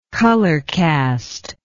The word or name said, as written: colorcast